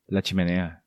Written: La Chimenea

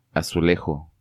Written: Azulejo

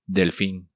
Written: Delfin

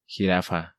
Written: Jirafa

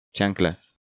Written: Chanclas

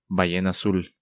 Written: Ballena Azul

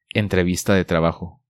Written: Entrevista de trabajo

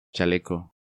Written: Chaleco

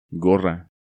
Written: Gorra

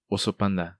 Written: Oso Panda

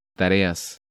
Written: Tareas

